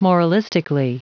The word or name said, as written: moralistically